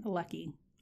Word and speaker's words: Leckie: By Anne Leckie. I think I'm saying her name right. – Okay. – L-E-C-K-I-E. – All right. You heard it here.